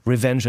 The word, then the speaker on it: RVNG: Und das ist bei Revenge International erschienen. Das ist dieses Label, das sich ohne Vokale schreibt, also RVNG International. Ausgesprochen wird das tatsächlich Revenge International.